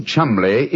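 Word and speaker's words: Cholmondeley: Being an American, he had no reason to know that the name Chumley is in no way pronounced the way it is spelt. – But I never thought of that. Chumley. That name spelt C-H-O-L-C-H-O-M-O-N-M-O-N-D-E-D-E-R-L-E-Y.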